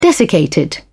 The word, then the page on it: desiccated: British English